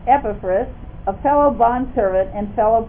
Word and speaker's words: Epaphras: See, I know because I looked up the pronunciation on Google. And it's Epaphras. So I put it on my notes here. Because for years I said Epaphras. And that was wrong. It's Epaphras.